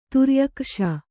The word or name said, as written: ತುರ್ಯಕ್ರ್ಷ